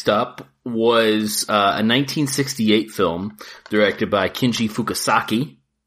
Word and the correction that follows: Fukasaku: or Fukasaku, I think is how you say it. Fukasaku.